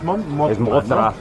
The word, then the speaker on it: Mothma: Mod Monta se me parece así como que monstruo de Godzilla. – No, es Mothma. – Mothma. Sí, la pronunciación está medio difícil.